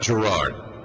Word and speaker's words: Gerard: Gerard. – Yes, sir. – It's not like David Gerard, it's Gerard. – No. – Gerard. All right, very good. All right, Gerard, you're on.